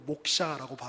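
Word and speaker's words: 목샤라고 (मोक्ष): 그리고 결국 거기서 말하는 해탈이라고 하는 것은 산스크리트어로 목샤라고 발음을 하는데 그래서 우리 목사님들은 다 해탈한 분들이죠.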